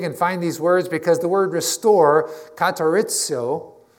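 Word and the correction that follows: katartizo (καταρτίζω): and I pronounced that wrong, sorry, katartizo.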